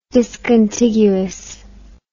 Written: discontiguous

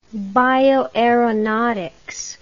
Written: bioaeronautics

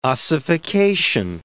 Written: ossification

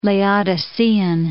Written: Laodicean

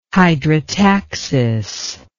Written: hydrotaxis

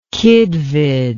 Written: kidvid

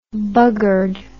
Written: buggered

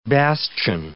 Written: bastion

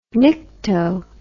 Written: nycto

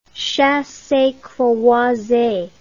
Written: chassecroise

